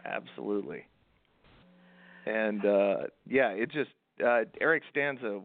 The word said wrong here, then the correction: Stanze: was the uh or Stanze, I think is actually how it's pronounced.